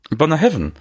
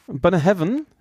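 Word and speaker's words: Bunnahabhain: Bonnehabtain habe ich. – Bonnehaven. – Bonnehaven, 25 Jahre.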